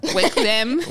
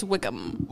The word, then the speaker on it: Wickham: I completely said his last name wrong. – Yeah. Wickham. – I don't even know what I said. Phil Welcome? I don't know. No, it's Phil Wickham. Right? – I think it's Wickham.